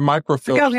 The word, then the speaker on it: microfiche: The microfiche thing. I was just wanting you to go further into the microfiche or the microfiche. Is that how you say it? – Fish. – Microfiche.